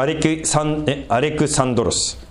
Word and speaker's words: アレクサンドロス: アレキサンダー、ギリシャ語的に言うとアレクサンドロスですね。